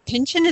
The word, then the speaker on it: Pynchon: So I understand you have a book of names, and that you are very influenced by Thomas Pynchon. Is that how you say his name? – Yes, I exactly.